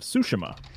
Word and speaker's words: Tsushima: Ghost of Tsushima.